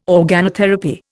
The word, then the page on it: organotherapy: Indian